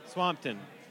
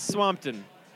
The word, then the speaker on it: Swampton: So, like, again, Clarence Swamptoon? I'm not sure. I'm not sure how to pronounce it. – Swampton. – Clarence? – Thanks. – Clarice Swampton.